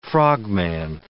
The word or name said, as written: frogman